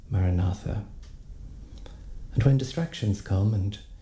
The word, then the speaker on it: Maranatha: And the one that's recommended is Maranatha, which means come, Lord Jesus. It's an ancient Aramaic word. And we break it into its four syllables. Ma-ra-na-tha.